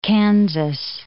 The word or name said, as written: Kansas